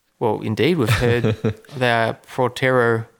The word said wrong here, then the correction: Potrero: How do we pronounce it, Trent? – Potrero Hill.